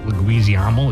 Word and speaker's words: Leguizamo: John Leguizamo. Leguizamo, is that how you say it? Yeah.